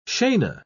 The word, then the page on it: scena: British English